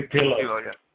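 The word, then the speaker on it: Pilous: I hope I'm pronouncing this right, Rudy Pillow. Is that correct? – Rudy Pillow.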